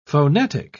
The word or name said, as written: phonetic